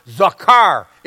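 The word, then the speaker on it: zakar (זכר): It's pronounced zakar. Say it. Zakar. Zakar.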